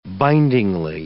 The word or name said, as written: bindingly